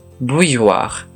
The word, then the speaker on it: bouilloire: 次によく出てきたのは、 b o u ーという単語です。湯沸かし器という意味で、綴りは B, O, U, I, L, L, O, I, R, E。最後の 4 文字、 O, I, R, E の発音、 war もポイントですが、何より前半の B, O, U, I, L, V が難しいです。